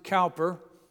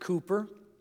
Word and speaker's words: Cowper: For years I pronounced it Cowper. He pronounced it Cooper.